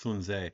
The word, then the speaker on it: Sun Tzu: Sun Tzu's The Art of, well, technically it's pronounced Sun Tzu.